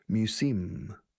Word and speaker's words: museum: Fijaos en pronunciación de museo. This word is mispronounced by Spanish-speaking people. Mew, como todos, Miura, Mew, y tarjeta SIM de los teléfonos. Museum. Acentuando la segunda sílaba.